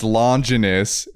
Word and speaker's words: Longinus: So I'll keep that as one, but I also, in case that one didn't work, I also chose the Spear of Languinius, which is the spear from Neon Genesis Evangelion. – Languinius? – I don't know how to pronounce it. – It's Longinus. It's Longinus. – Yeah, Longinus.